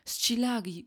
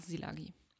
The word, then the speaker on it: Szilagyi: haben wir dann im Gutshof Silagi gefeiert, beziehungsweise Silagi, glaube ich, spricht man es aus. Ich, ich spreche es immer falsch aus. Ich habe auch früher immer Schilagi gesagt. Aber ähm, Oliver hat seinen Namen auf jeden Fall selber Silagi ausgesprochen. Deswegen ähm, verzeiht mir, wenn ich das falsch sage. Ähm, es heißt, glaube ich, einfach Silagi. Aber man schreibt es S-Z-I-L-A-G-Y-I.